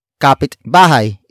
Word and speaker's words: kapitbahay: Kapitbahay is spelled as K A P I T B A H A Y.